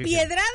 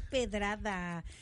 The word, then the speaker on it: pedrada: ¿Es piedrada o pedrada? Es pedrada.